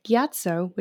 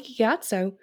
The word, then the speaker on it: Gyatso: Monk Gyatso, which I'm probably butchering that because it's been a while since I've watched the first. – No, you said it right. – Monk Gyatso in the series was found in a closed room surrounded by skeletons in Fire Nation uniforms.